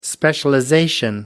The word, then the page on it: specialization: American English